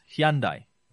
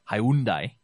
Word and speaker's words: Hyundai: Hyundai, which we sometimes mispronounce as Hyundai, Hyundai came through with one of the best value proposition, and this came through in their advertising and marketing.